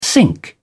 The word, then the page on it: sync: British English